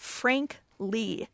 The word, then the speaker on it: Frank Li: Frankly, in the title, is spelled F-R-A-N-K-L-I, but it's actually about Frank Lee, L-I.